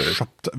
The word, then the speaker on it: Chaptal: Schabtal. – Wie Chantal, nur mit P.